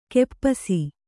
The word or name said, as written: ಖಪ್ಪಸು